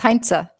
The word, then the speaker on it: Hainzer: Almost. Hainzer. Yes. – Hainzer. I still need to brush up on my Austrian, right? Austrian yes. Surname. Yes? – Yes, that's correct.